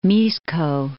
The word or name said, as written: Mixco